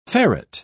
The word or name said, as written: ferret